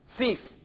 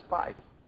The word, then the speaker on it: fief: And there is a word, F-I-E-F, fief. I thought it was pronounced fife, but it's pronounced fief.